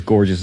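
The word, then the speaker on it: Görges: That is Gorgeous is how you say her name.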